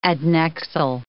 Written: adnexal

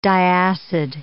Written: diacid

